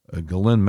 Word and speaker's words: Ghislaine: Her first name is pronounced in an unusual manner. It's written in an unusual manner. Ghislaine, Ghislaine Maxwell. The S is silent.